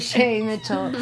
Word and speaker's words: Shay Mitchell: Shia Mitchell. – Shane Mitchell? – That's what I meant to say. Shay. – My brain's not working. – I said her name wrong, so. No, you're right. Shay Mitchell.